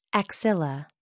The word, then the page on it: axilla: American English